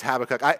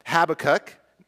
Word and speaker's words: Habakkuk: And so technically it is Habakkuk. It's Habakkuk. If I mix it up a few times, that's why. So it's Habakkuk or Habakkuk.